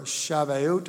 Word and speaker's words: Shavuot: Among the Jews, the most popular name for this holy day or this day is the Feast of Weeks, or Shavuot, I think is how they pronounce it in Hebrew.